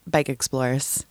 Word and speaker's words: Bikexplorers: So we just pronounce it Bikexplorers. You just drop that second E. We just mush those two words together.